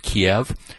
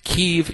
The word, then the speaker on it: Kyiv: And by the way, I always pronounced it Kiev, but never again. What's been explained to me is Kiev is the way Russians pronounce the name of that city. Kyiv is the way Ukrainians pronounce it. So from now on, as far as I'm concerned, it's Kyiv.